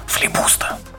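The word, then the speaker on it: Флибуста: А я его на другое слово делаю ударение, говорю его, я на него говорю «Флипуста». Вот.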